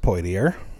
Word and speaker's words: Poitier: It's pronounced Poitier.